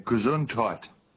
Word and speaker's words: Gesundheit: G-E-S... H-U-I-N-D-E-I-T. – Oh, so it's Gesundheit. – Gesundheit. – It's got a D in it.